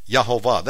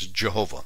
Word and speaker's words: Yehovah: It's Yehovah. That's the actual pronunciation. Although we know it in the West as Jehovah. You would spell it in the King James a couple times. It spells it J-E-H-O-V-A-H. It's correct. The Hebrew, though, is with a Y, because there is no J in Hebrew.